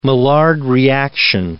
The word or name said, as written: Maillard reaction